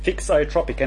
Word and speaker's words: thixotropic: Thixotropic, and it's spelt T-H-I-X-O-tropic. Thixotropic.